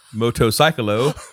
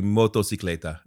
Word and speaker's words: motocicleta: So that's Rob's Spanish. I'm pretty sure motorcycle. It's his cycle, so cicleta, so be motocicleta.